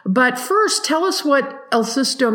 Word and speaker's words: El Susto: Okay, El Susto, which is a documentary I watched the other night.